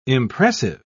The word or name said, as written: impressive